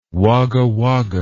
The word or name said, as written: Wagga Wagga